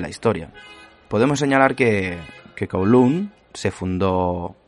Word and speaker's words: Kowloon: Yo voy a decir Kowloon, ¿vale? Porque es como lo pronunciaríamos nosotros, aquí los españoles.